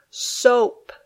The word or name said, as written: soap